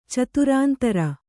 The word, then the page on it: ಚತುರಾಂತರ: caturāntara